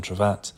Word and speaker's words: Trevatt: You can follow me on Instagram at Tom Trevatt or Twitter at Tom Trevatt,